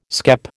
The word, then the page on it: skep: American English